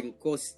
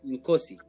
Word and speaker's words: Nkosi: Nkosi. Nkosi. – Yes, Nkosi. that is it.